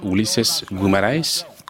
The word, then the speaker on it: Guimarães: C'est celle de Ulysses Guimarães, on prononce comme ça, c'était au moment de la promulgation de la nouvelle constitution de 1988.